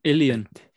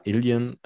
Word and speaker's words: Ilian: wie heißt er denn? Elian? Nee. Ilian. Ja, Ilian, ähm, genau, Ilian einfach in cool ausgesprochen.